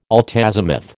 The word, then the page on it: altazimuth: American English